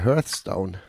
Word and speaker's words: Hearthstone: Hearthstone? – Ja.